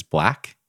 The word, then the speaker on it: 6LACK: I learned about this guy from Drew Mack, who lives here in St. George, but the artist's name is Black, spelled with an S. So, or I'm sorry, it's spelled with a six. So it's 6LACK.